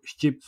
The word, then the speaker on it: Shqip: Bu şçip sözcüğündeki kendi dillerinde S, H, Q, I, P diye yazılıyor. Yani aslında orada benim Ç'ye yakın bir telaffuzla söylediğim harf bir Q harfi.